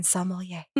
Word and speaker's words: sommelier: So if you don't want to pronounce the whole word, just say Somm, because there's no N in sommelier.